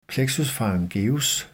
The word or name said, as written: plexus pharyngeus